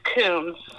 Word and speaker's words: Kuhns: It's Kuhns.